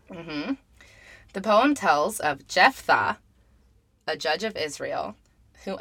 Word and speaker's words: Jephthah: Okay, it's spelled J-E-P-H-T-H-A-H. – Jephthah, that's what I was thinking.